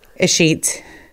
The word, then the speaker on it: escheat: First thing I needed to ask you is how do you say escheat? – Escheat. Can you say it one more time? Escheat. Escheat. So like my my Twitter is don't give a sheet.